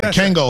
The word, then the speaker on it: Kangol: Yeah, Kangol hats. You said Kangor.